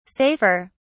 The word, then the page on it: safer: British English